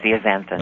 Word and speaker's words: zeaxanthin: Is it Lutzen and zeaxanthin? Are you familiar with those things? – You're doing that on purpose. It's lutein and zeaxanthin.